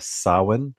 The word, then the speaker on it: Samhain: You know, it has its roots in this ancient Celtic / pagan festival of what looks like it's spelled Samhain, but it's really pronounced Samhain, apparently.